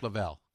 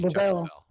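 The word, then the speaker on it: Leavell: And then that was the year the Allman Brothers, Chuck Leval, how do you say his last name? – Chuck Leval. – He joined them. – Or Chuck Lavelle, excuse me. – Lavelle.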